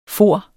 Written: forene

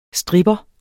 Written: stripper

